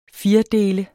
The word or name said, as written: FIRDELTE